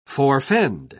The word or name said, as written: forfend